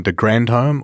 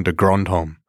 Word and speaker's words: de Grandhomme: He doesn't know himself, and the commentators always wonder if it's Colin de Grandhomme or Colin de Grandhomme. And he's acknowledged that he's got no idea. – Okay, well, so either is fine, or indeed any pronunciation.